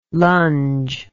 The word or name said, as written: longe